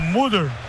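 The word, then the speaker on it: Müldür: Mudo? Não, não é mudo, é o Muldur.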